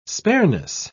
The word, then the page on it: spareness: British English